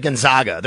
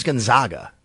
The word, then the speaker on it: Gonzaga: At least you said Gonzaga. They're called Gonzaga. The Zags, but it is Gonzaga.